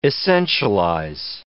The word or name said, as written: essentialize